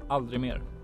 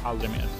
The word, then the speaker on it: Aldrig mer: Aldrig Mer, or Aldrig Mer in actual Swedish.